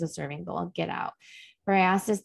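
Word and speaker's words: Briseis: like powerful emotion evoking part was when Briasis, I think that's how you say it. – Briasis, yeah.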